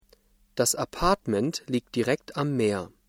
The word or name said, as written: Apartment